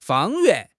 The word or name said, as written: 方圆